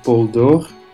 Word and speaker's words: Poldoore: I, always say Paul Dor. So yeah, I don't know. In the United States, they pronounce it totally differently. So you can choose.